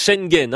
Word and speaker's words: Schengen: Ou plutôt espace Schengen, hein, pour prononcer correctement Alain Lamassoure.